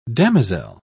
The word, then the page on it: damosel: British English